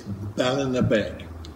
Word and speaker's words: Ballinabeg: Can you please tell me how you pronounce the name of the place in the title? – Easily. It's Ballinabeg.